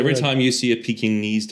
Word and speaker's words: Pekingese: It's Pekingese. Peking. Pekingese. – Pekingese. – Peking. – Pekingese. – Stop it. No, you're putting an extra N.